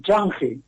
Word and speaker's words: Change: Vamos, si entráis en Change, lo pronuncio igual que se escribe, sí.